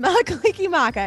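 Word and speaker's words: Mele Kalikimaka: Mela Kaliki Maku. – Basically, yes, Mela Kaliki Maka.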